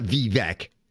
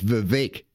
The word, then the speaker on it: Vivek: It's not Vivek. It's Vivek, like cake.